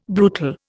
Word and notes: brutal: Indian

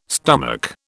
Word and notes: stomach: British English

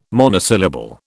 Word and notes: monosyllable: British English

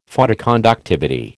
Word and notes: Photoconductivity: American English

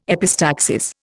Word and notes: epistaxis: Indian

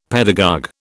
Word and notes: pedagogue: British English

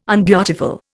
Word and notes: unbeautiful: Indian

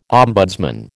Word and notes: ombudsman: American English

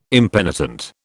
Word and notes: impenitent: British English

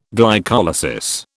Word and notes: glycolysis: British English

